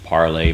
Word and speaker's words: Parler: So you can find us on Parler, pronounced Parlay, but written Parler.